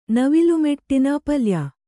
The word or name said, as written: ಪಲ್ಯ